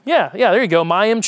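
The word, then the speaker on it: mayim (מים): I don't speak Hebrew, but the way you would pronounce this, and I'm probably going to butcher it, but I'm going to do my best, would be Mayim. Because you always put in Hebrew, the emphasis is on the second syllable. So it's mayim, kaim.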